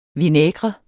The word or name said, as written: VINAIGREN